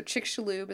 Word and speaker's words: Chicxulub: So Chicxulub is how you pronounce that word.